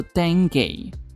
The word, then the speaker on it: dengue: 据说啊是 19 世纪才开始被广泛运用，而登革的英文念作 dengue，据说是来自于非洲的史瓦西利语，意思是抽搐、癫痫发作的状态。